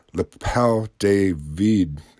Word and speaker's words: l'appel du vide: The French call it l'appel du vide.